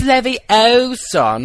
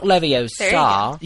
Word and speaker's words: Leviosa: You're saying it wrong, Andrew. – It's Leviosa, not Leviosa.